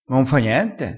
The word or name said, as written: m'importa